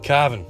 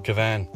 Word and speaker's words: Cavin: And my solitary note for you is that C-A-V-I-N is pronounced Cavan, not Cavan.